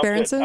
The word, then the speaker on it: Berenson: how to pronounce Berenson? it. Alex Berenson. Berenson. Mm-hmm. Yes.